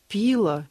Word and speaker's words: пила: А Людмила Павловна спрашивает, как правильно поставить ударение «пила» или «пила»? – «Пила», конечно.